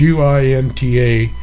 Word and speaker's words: Uinta: U I N T A Unita, you got me.